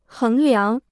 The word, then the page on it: 衡量: héng liáng